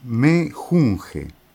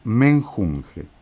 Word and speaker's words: menjunje: En España dicen menjunje, le han quitado la N. En México hasta Venezuela, menjurje, por eso la canción que escuchamos, que corresponde a un grupo mexicano, que se llama el personal, pronuncia menjurje. Y en el cono sur, desde Venezuela para el sur, en México, en la Argentina, decimos menjunje.